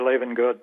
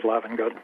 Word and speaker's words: Lavengood: I pronounce it the long A, Lavengood, but most of the world says Lavengood.